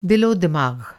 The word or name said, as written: दिलो-दिमाग़